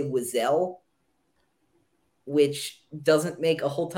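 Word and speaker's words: Wesell: So we are now like countless to, so the history behind this is there is no canon pronunciation to this character's name, but it's Wessel. You're right. It's Wessel.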